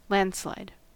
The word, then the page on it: landslide: American English